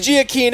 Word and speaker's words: Giacchino: It is Giacchino.